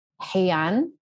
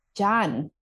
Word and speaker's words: Jean: This, the literal or the phonetic Spanish phonetic pronunciation of the word Jean is Heian. – Yeah, I thought it would be Heian, right? But they all call me Jan. Everybody calls me Jan.